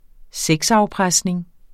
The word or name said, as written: sexafpresning